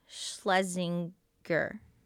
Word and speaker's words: Schlesinger: No, but say her name right. – Eliza Schlesinger. Yeah. – Okay. – It's just Ger.